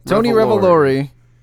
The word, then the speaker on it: Tony Revolori: Tony Revolori.